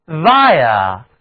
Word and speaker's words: via: 那么通过、经过就是在词根 VI 后加上一个字母 A, 字母组合 IA 读音是 IA, VIA, VIA, 那么行进通过必然是要通过道路，这个词呢就是介词经由、通过的意思。